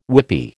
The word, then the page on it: whippy: American English